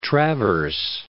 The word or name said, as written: Traverse, Lake